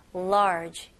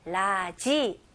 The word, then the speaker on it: large: 네, 우리나라 말로 외래어로 라지가 있잖아요. 근데 이거는 영어가 아닙니다. 제대로 영어로 발음하실 거면 large. 이름절로 하셔야 돼요. 오케이. 이거는 많은 연습 통해서 가능합니다. 오케이. 라지. 아니고요. large. large. 그리고 마지막으로 J 발음으로 끝내셔야 돼요. 그래서 모음 소리 없이 large.